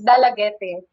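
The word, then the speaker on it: Dalaguete: The proper pronunciation, according to my history teacher, is Dalagete. And even sa mga official communication sa amuang municipality, it's pronounced as Dalagete.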